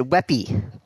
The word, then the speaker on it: WebP: So they decided to come up with a new photo standard for the web, and they call it WebP. Pronounced WebP? – Well, they pronounce it Weppy.